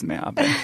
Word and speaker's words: Meabe: Rhymes with Meabe. It is Meabe.